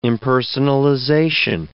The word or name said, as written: impersonalization